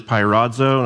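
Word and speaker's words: peirazo (πειράζω): Testing, the Greek word is peirazo, that's how you pronounce it.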